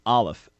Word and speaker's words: Aleph: So it's actually pronounced Aleph, accent on the first syllable.